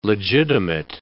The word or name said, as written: legitimate